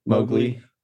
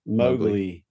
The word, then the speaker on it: Mowgli: Is it Mowgli? Mogul? – Mowgli. – Mowgli.